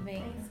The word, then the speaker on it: Banksy: Banksy, é, eu falei certo? – Banksy.